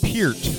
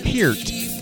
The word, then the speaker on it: Peart: In dedication to Neil Peart's passing, Carton Brewery came out with a new beer, and they are calling it pronounced Peart, and it's Peer, like P-I-E-R-T. – Like beer, but peer. – Peart. – Peart. – Peer with a T.